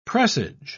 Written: presage

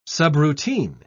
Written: subroutine

